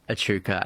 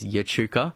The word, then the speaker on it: Echuca: my dad was pronouncing Echuca as Yechuca. I was like, what, say that again, Dad? Yechuca. I said, Dad, I think there's a, you're putting a U in there somewhere where it's not meant to be.